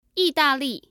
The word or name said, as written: yidali